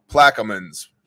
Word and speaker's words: Plaquemines: Thanks, Bayou Country. Well, he's got the word Bayou in his name. He probably knows how to pronounce it. Plaquemines.